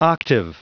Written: octave

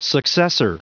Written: successor

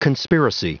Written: conspiracy

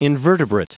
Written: invertebrate

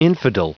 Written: infidel

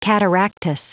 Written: cataractous

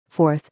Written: 4th

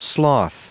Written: sloth

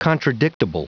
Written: contradictable

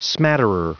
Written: smatterer

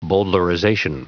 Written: bowdlerization

